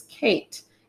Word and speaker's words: Kaiit: And her name is Kate, and it's spelled K-A-I-I-T.